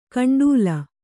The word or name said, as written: ಗುಂಡಲ